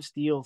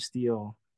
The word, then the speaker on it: Steele: there's an E at the end of Steele, folks. Oliver Steele Music. That's E at the end of Steele.